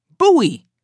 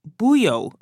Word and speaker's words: buoy: Like buoy. I think I've been saying buyo or something.